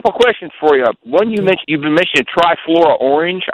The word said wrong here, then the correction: trifoliate: I think that's how you pronounce it. – Trifoliate. – Trifluoriate. That's right. Yeah, foliate. Okay.